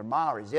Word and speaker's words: Maori: I just mispronounced the tribal name. They're Maoris, M-A-O-R-I, which is an indigenous tribal ethnic group in New Zealand.